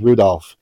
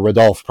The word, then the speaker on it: Rodolphe: a player named Rudolph, and or Rodolphe probably is the right pronunciation, and then another with a clerk named Léon.